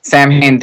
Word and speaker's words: Samhain: Samhain, I never know how to pronounce it. – Samhain.